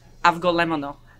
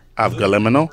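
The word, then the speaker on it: avgolemono: How do you say it though, for real? – Avgalamono. – Avgalemono? – Yes.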